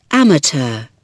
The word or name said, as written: amateur